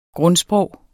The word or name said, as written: grundsprog